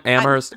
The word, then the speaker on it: Amherst: Are they saying that Amherst is filled with loud people? – I don't know what that means.